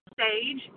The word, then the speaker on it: Sage: That's Paige, P-A-G-E. – No, ma'am. Sage like the spice. S is in Sam.